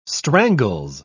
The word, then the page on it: strangles: British English